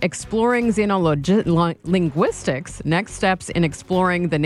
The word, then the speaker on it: Xenolinguistics: I pronounce it Xenolinguistics. I don't, it's a new field. So, maybe it'll change as things go along.